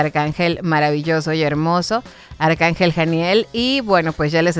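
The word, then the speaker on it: Haniel: También cuando estemos buscando trabajo, Arcángel Janiel, se escribe con H, H-A-N-I-E-L, H-A-N-I-E-L, Janiel, pero se pronuncia con J, como J, y nos ayuda también en las pruebas importantes que tenemos en la vida.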